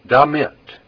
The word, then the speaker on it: damit: Be careful that you pronounce that with the accent on the second syllable. Damit. If you put the accent on the first syllable, it sounds like an English word that means something very different.